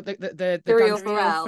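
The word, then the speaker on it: Syrio Forel: and she's talking about, is it Sirio or Silrio? – Sirio Farrell. Farrell.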